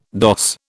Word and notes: doss: British English

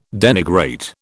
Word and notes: denigrate: British English

